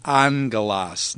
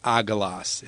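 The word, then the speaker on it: angelos (ἄγγελος): So in the word angelos, you have two gammas in a row. The first one is pronounced like an N, so it's angelos, not agelos. It's angelos. And that is what is called a nasal gamma.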